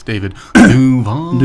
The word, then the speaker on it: Duval: Also, you said it wrong. It's David Duval.